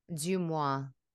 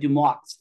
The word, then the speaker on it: DeuxMoi: How do, how do you pronounce that French word? DeuxMoi. DeuxMoi. DeuxMoi, because you know, as an ESL person, going back to that, I always I used to look at it and be like Dumois.